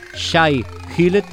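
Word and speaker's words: Shai-Hulud: Recuperamos un pasado que suena a futuro con el tema que estás escuchando de fondo y que se pronuncia Shai Hillet, según la señorita del traductor de Google.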